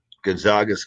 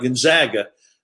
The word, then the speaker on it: Gonzaga: And we got to say it right, Gonzaga. They're a little sensitive about Gonzaga instead of Gonzaga, but yeah, I think they have a chance.